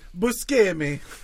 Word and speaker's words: Buscemi: But you'd, And actually, it's pronounced Buscemi. – Is it? – Yeah, that's how he says it. – What? – Yeah, it was on, I think it was on Conan that he... He's like, yeah, I actually pronounce it Buscemi, which makes sense, you know, bruschetta.